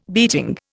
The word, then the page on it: beating: Indian